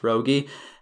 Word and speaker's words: Roggie: My last name is spelled R-O-G-G-I-E, Josh Rogge, and really appreciate any support.